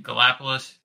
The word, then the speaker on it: Gallipolis: I believe I'm pronouncing it right. Gallipolis.